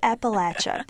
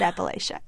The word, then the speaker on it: Appalachia: I pronounce it Appalachia, but it's okay. You can pronounce it Appalachia.